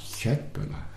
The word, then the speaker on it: Köttbullar: Köttbullar.